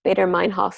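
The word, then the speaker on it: Baader-Meinhof: The Bader Hoffman, I'm completely mispronouncing it, but Bader Meinhof phenomenon, you know, when you see that one thing and then you see it everywhere.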